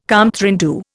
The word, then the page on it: compte rendu: Indian